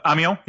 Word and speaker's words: Amiens: I believe legal action is already, it's a bit further ahead, is it, than where we are just now, because is it Amiens? Is that how you say it? – Amiens, yep.